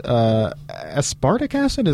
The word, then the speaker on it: aspartic acid: Super nerdy, non-essential amino acid, aspartic acid? Am I butchering that? – No, you're not at all.